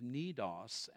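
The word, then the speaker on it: Cnidus: We made slow headway for many days and had difficulty arriving off of Cnidos, as it's pronounced, when the wind did not allow us to hold our course.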